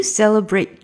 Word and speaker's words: celebrate: Do you celebrate Chinese New Year? 有没有感觉到 celebrate Chinese New Year celebrate 的这个 t 没有发音？Celebrate Chinese New Year 是这样的。In the United States, do you celebrate Chinese New Year in the United States?